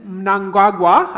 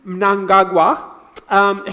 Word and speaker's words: Mnangagwa: And so in celebration, the president of Zimbabwe, Edmund Mnangagwa, I had to practice that this morning in front of the mirror, my parents are from Zimbabwe, so I should be able to pronounce this. Edmund Mnangagwa, he wrote this: "What a win for Zimbabwe.